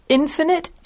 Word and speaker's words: infinite: Yeah, we said infinite and finite, very different on the I sound.